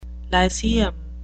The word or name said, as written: lyceum